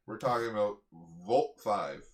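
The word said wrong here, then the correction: Voltes V: Is that how that's pronounced? – Yeah, it's 5. – It's spelled V-O-L-T-E-S space V. – Yeah, sorry, Voltes 5.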